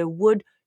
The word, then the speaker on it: would: C'est le would, should, could. Ça s'écrit W-O-U-L-D, après ça S-H-O-U-L-D, après ça C-O-U-L-D, mais on ne prononce pas le L.